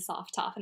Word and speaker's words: sophtoph: Like when I go into people's Twitch chats, like people who don't know me or whatever aren't familiar with Go, they always call me sophtoph. And I'm like, is that really the default pronunciation? But then I looked at it and I was like, I guess it is, right?